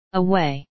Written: away

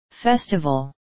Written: festival

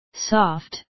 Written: soft

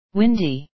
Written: windy

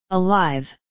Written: alive

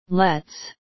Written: let's

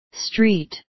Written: street

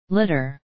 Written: litter

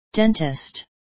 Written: dentist

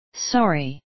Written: sorry

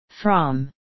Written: from

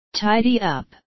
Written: tidy up

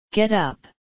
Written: get up

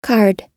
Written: card

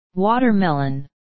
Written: watermelon

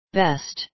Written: best

